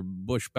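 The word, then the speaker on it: Boushh: She infiltrates Jabba's palace disguised as the bounty hunter Bush Bausch Boosh, depending on how you choose to pronounce it.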